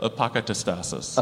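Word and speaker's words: apokatastasis: So about the Ndedekumpo word, apakatastasis. – Yeah. – How should I be pronouncing that? – Apakatastasis. – Apakatastasis. See, when he says it, I'm like, of course, apakatastasis.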